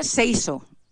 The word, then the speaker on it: seiso: La tercera, seiso. Es pronuncia, com, o sigui, es llegeix tal com ho pronuncio.